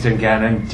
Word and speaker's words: Danganan: Chris's last name is Seifer, S-E-E-F-E-R. – And Joe? – Joby. My last name's Dungannon, D-A-N-G-A-N-A-N.